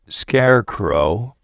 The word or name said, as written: scarecrow